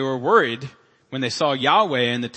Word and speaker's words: Yahweh: The consensus now is that it's pronounced Yahweh.